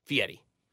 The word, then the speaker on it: Fieri: Is it Fieri? Fietti? Guy Fieri? Fieri. – I don't know that he deserves that pronunciation, but sure.